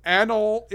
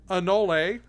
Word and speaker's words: anole: To answer our question from episode one, Anol is actually pronounced Anole or Anole or something like that.